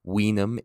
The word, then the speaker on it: vinum: Vinum in Latin means wine, V-I-N-U-M. These are pronounced as W's for those that don't know and are tuning in for the first time.